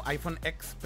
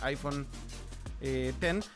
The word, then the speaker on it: iPhone X: Básicamente por eso también este iPhone nuevo se llama iPhone X La gente lo lee como iPhone X, pero en realidad la pronunciación correcta es iPhone eh, X Pero bueno,